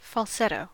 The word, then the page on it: falsetto: American English